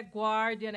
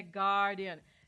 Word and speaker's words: Guardian: Lembrando que esse U é mudo. Esse U não é pronunciado. Não é Guardian, é Guardian. Tá.